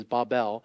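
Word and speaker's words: Babel (בָּבֶל): Therefore, its name was called Babel, which would be, I say Babel, but the proper Hebrew pronunciation is Babel, because there the Lord confused the language of all the earth.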